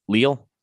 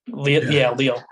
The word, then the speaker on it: Lille: Lille? – Lille.